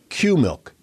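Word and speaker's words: Qmilch: She invented a new fabric, and it's spelled Q-M-I-L-C-H, Q-milk, which is actually made from the casein protein found in milk.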